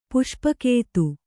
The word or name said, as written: ಪುಷ್ಪಕೇತು